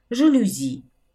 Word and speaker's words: жалюзи: Например, «жалюзи» – это французское слово, поэтому ударение падает на последний слог квартал, диспансер, каталог, инструмент, цемент.